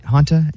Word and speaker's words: Hanta: Hantus? – Yeah. Hanta, H-A-N-T-A.